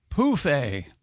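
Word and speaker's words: pouf: Anyway, Europeans of a certain age use the French word pronounced poufé for an ottoman, phonetically spelled P-O-O-F hyphen A.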